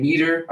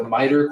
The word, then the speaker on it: MITRE: So I've mentioned a couple of times, I was saying Meter. I got a comment here saying that it's the MITRE Corporation.